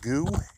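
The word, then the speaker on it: GU: Hoppy Trails is by the company called Goo. It's spelled G-U.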